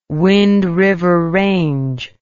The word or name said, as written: Wind River Range